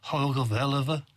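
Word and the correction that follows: Hoge Veluwe: Hogevelden, yes. – But, yeah, your pronunciation's way better than mine.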